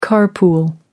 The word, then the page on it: car pool: British English